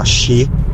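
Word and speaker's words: Shi (四): Sì, il numero 4 in Giappone è considerato che porta sfiga perché si pronuncia in, anche in altri modi, ma tra i modi anche, si pronuncia Shi.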